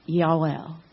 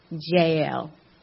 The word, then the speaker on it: Yael: However, Sisera fled away on foot to the tent of Yael, or we might, we often pronounce it Jael. The, the uh, technical Hebrew is Yael.